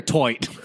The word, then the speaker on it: toit: Is it tight or toit? – I think it's toit. That's how I've always heard it pronounced. – Toit.